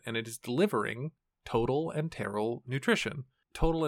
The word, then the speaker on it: enteral: This is total enteral nutrition. I think I'm saying that right. Enteral, E-N-T-E-R-A-L.